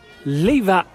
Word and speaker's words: Lewa: zit hier samen met uh, Wilco in eh, de dierentuin van Zurich bij het eh, nieuwe Leva Savannegebied. Spreek ik het nou goed uit? Ja, spreek je goed uit.